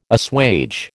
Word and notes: assuage: American English

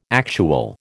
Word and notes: actual: American English

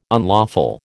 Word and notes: unlawful: American English

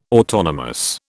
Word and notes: autonomous: British English

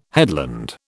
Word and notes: headland: British English

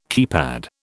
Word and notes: keypad: British English

